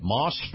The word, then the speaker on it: Maus: Now, this portion of the program is brought to you by my friends at Moss Family Automotive and their brand new dealership, Moss Acura. Now, Moss is spelled M-A-U-S.